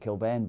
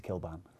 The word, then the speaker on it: Kilbane: He was in America, they pronounced it Kilbane, but, you know, he's a Kilbane.